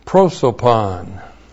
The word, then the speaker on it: prosopon: We have the preposition and, which is in, and then prosopon. P-R-O-S, long O-P-O-N. Prosopon.